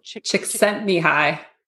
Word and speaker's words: Csikszentmihalyi: Mikhail, I can never pronounce his last name. – Csikszentmihalyi. – There we go.